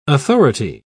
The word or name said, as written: authority